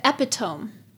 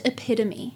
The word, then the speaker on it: epitome: And she said, oh, it's like the epitome. – Oh, God. – Of this. And whoever else was in the car with us said, it's pronounced epitome.